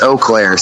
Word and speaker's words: Eau Claire: It's Eau Claire.